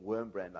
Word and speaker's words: Wurmbrand: Um, you'd call him Wurmbrand, I guess, um, but it's the W is pronounced as a V, where I come from anyway.